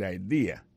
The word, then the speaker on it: idea: T, apostrophe S, good, G-O-O-D pour good, ça veut dire bon, bonne, idea, c'est I-D-E-A, ça veut dire idée, that's a good idea. Alors, accent vient tomber dans la deuxième syllabe là, one idea. Alors, moi, c'est idée, vous faites trois syllabes vraiment, I-D-A.